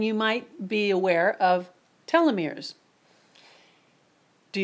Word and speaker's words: telomeres: Now, I want to talk about telomeres. I've heard people pronounce it telomeres, but I just looked it up and and I found out that it is pronounced telomeres.